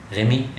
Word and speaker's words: Remy: Well, it's French, but I'm not from the French-speaking side of Switzerland, so you just say Remy. – Remy, yeah.